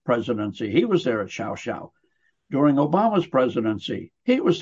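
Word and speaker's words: Llao Llao: There's an incredibly upscale resort called Shao Shao. It's spelled LLAO-LLAO, but down there in their Castellano Spanish, it's pronounced Shao Shao.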